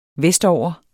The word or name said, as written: vestover